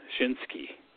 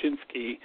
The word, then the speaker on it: Shinsuke: Shinsuke. I know Nakamura part, I got that down. So it's Shinsuke. Some people say Shinsuke, but that was a good match.